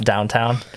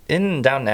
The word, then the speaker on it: Downton: Did you watch Downton Abbey at all? – I did not, but I appreciate your correct pronunciation. – Not downtown.